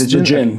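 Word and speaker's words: Djinn: Is it Dijin? Is it? – Yes, Dijin.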